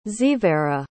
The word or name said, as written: xevera